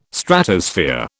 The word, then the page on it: Stratosphere: British English